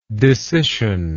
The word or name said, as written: discission